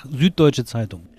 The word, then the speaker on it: Süddeutsche Zeitung: Süddeutsche Zeitung.